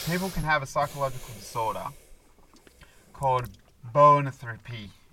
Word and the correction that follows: boanthropy: Bonotherapy. It's probably not how you say it, but I'm going to call it that. – Let's have a look. – Yeah. Can you get him to say it properly? – Bonotherapy, yeah. – Thropy. – Boanthropy. – Boanthropy. – Boanthropy. – You still don't sound like it, what it should sound like.